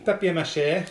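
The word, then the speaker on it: papier-mâché: I can say it's made of papier mache. The real pronunciation. – Papier mache.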